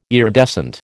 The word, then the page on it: iridescent: American English